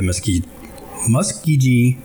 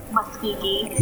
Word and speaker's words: Muskogee: An African American newspaper at uh, Muskogee, Oklahoma uh, before beginning his own newspaper, the Muskogee. Why Am I even pronouncing it right? Because I'm seeing it so many times now. – Muskogee. – Muskogee. Okay. Thank you.